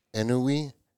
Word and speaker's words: ennui: And so maybe it's not just so much the technology has made our kids, you know, lifeless and full of ennui. Yes, E-N-N.